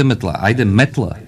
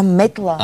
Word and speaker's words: метла: Ти май не видя ударението. Не, айде метла. – А, метла.